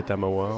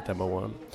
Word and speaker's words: Tamawa: L'avantage euh, des mots japonisants, c'est qu'ils se prononcent de la même manière dans toutes les langues. Donc, euh, aussi bien en allemand c'est Tamawa, en anglais c'est Tamawa, en français c'est Tamawa.